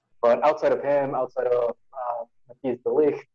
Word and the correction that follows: de Ligt: but, but of course, it's Matthijs de Ligt.